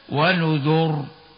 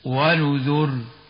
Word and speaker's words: ونذر: كما نطقنا، وهناك كلمة ونذر في سورة القمر في 6 مواضع، قال العلماء يجوز تفخيم الراء ويجوز ترقيقها عند الوقف عليها، التفخيم هكذا ونذر، والترقيق هكذا ونذر. وقد اختاروا الترقيق، لكننا نختار التفخيم عند الوقف هكذا ونذر.